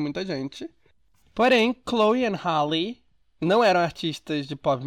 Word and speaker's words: Halle: Aliás, cada vez que eu falo o nome da Hale, Halle, Hale, eu falo de um nome diferente, de um nome diferente, de uma maneira diferente. Então, rapidinho, deixa eu ver aqui como se pronuncia. Tá, gente, é Chloe and Halle.